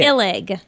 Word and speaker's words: Ilg: Am I pronouncing it correctly? Try it. – Ilig. – Is it Ilig? – Yeah, I need to buy a vowel before the G.